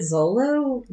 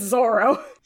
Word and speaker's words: Zoro: Even though we call Zoro Zoro, but that's because that's his name. Like, we don't need to get into that, but trust me, Zoro is his name. – I was so confused. I texted Hannah. I was like, is it Zolo? Did we pronounce it wrong the whole time? – No, you see his name also written in the Latin alphabet, and it's Zoro.